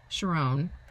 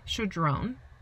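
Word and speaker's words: Chödrön: And it's something I reference a lot, but over this mantra from Pema Chodron. Guys, I mispronounced her name. I always mispronounce everything. Pema Chodron.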